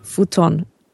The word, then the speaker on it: Futon: Wie spricht man Futon eigentlich richtig aus? Weil Futon ist doch, glaube ich, nicht richtig, Fudong. oder? – Fudong. – Futon. Ich, ja. Futon. – Einfach.